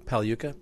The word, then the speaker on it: Pagliuca: It's pronounced Pagliuca. The G is silent in Italy, like lasagna.